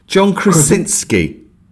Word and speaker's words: John Krasinski: John Krasinski. – John Krasinski. – Directed by John Krasinski.